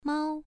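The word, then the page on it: mao: tone 1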